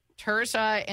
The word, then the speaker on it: Tirzah: Tirzah